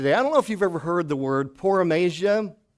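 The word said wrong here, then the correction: paronomasia: The word is pronounced like this. It's in English class, and it's paranomasia. Paranomasia.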